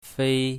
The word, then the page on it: fei1: mandarin